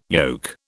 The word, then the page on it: yoke: British English